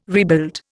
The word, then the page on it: rebuild: Indian